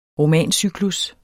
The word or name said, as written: ROMANCYKLUS